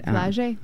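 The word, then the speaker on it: Flau'jae: And I just found that on YouTube by just typing up her name is spelled FLAU apostrophe GAE. – Yes. And Flage. We said it right.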